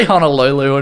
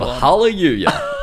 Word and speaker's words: Honolulu: I think you said that wrong again. To say Honolulu and the mountains of Hallelujah.